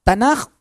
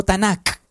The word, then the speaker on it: Tanaj: La Biblia hebrea se llama Tanaj o Tanakh, dependiendo de cómo pronuncie usted el hebreo, y está dividida en 3 grandes secciones.